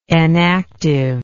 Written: enactive

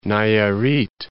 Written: Nayarit

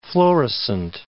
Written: Florissant

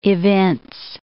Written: evince

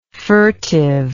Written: furtiveness

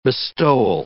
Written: bestowal

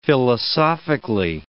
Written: philosophically

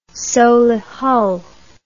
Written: Solihull

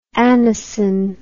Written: anacin

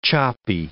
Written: choppy

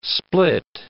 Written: split

